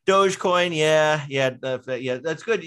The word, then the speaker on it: Dogecoin: You didn't pronounce it doggy coin. That's okay. So you're not as out of touch as you think. You know, when some people see it and they call it doggy coin.